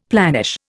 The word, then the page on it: planish: Indian